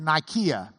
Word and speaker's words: Nicaea: They would have pronounced it Nikea back in that day, or just Nike.